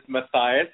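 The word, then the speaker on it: Mathias: For those of you guys who don't know how to pronounce M-A-T-H-I-A-S, I'm glad that my girls do. But you can't believe how many things I've heard over the years. Matthias and Matthias and Matthias.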